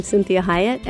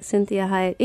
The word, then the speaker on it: Cinthia Hiett: I'm Cinthia Hiett, and you are listening to Conversations with Cinthia.